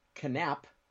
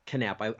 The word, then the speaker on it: Knapp: We had an adventure in the mountains, and we went to a place called Three Marm Brewing Company, located at 23555 Canap Cutoff in Crestline. – Canap. – I say the K so people will know to spell it with a K. Canap. K-N-A-P-P Cutoff in Crestline.